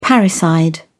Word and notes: parricide: British English

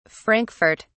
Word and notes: frankfurt: British English

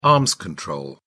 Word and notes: arms control: British English

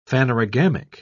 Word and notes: phanerogamic: British English

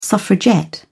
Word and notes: suffragette: British English

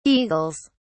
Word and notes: eagles: British English

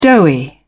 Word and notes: doughy: British English

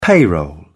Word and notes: payroll: British English

